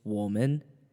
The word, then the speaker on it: 我们: We or us is just woman, woman. And it kind of sounds like the word women in English.